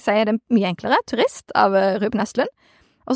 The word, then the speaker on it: Turist: Turist. Si turist.